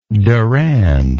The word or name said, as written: durand